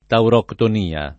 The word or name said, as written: tauroctonia